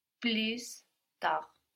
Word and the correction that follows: plus tard: Almost, we don't have the S though. – Plus tard. – Plus tard. Good. – Plus tard. – Plus tard. So again, we don't pronounce that final consonant, no. We have plus written, like plus in English, but we pronounce plus. Plus tard.